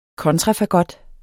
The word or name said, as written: kontrafagot